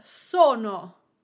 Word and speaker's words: sono: The word sono. Sono means I am. The accent goes on the first vowel. So-no. So-no. On the first syllable, so. Another example is the word bello.